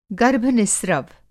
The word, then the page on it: गर्भनिस्त्रव: Hindi